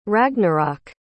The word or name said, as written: ragnarok